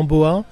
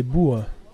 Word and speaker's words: boha: Ça s'écrit B-O-H-A, je crois. – Oui, alors en français, euh, on dit souvent boa, mais en gascon, c'est boua.